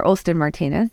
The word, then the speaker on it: Olstyn Martinez: Who is Dr. Aslan Martinez? Hopefully I'm pronouncing that correctly. Dr. Aslan Martinez. And how does that play a role in what exactly you're talking about? – Yeah. So this is Dr. Olston Martinez.